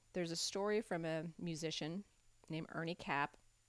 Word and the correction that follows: Knapp: Or sorry, why did I say Cap? That's dumb. Ernie Knapp, K-N-A-P-P. I do know that a K is silent. Thank you very much. And knife and other things are silent.